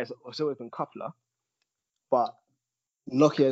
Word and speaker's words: Nokiaa: I think his name is pronounced Nokia. Nokia.